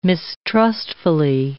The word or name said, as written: mistrustfully